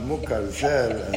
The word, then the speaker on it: Moukarzel: That's a good sign, you start pronouncing my name good. That was difficult, huh? Moukarzel, and with a lot of effort.